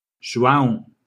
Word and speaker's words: João: João, Moine Close. Of course, she asks me to spell it. J-O-A-O. M-O-Y-N-E.